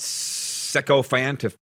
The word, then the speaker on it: sycophant: I feel like such a neophyte and sycophant. That's how you say it.